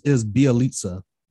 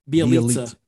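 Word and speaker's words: Bjelica: It's Bielitsa. – Bielitsa.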